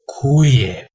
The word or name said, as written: cuie